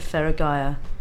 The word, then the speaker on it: Feragaia: Today, I'm talking to Jamie Wild of Feragaia. Have I said that right? – You have. You've nailed it.